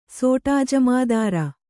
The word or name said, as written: ಸೋಟಾಜಮಾದಾರ